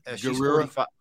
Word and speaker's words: Gurira: Is it Danai Gurira? – Gurira. – Yeah, that's what I would say.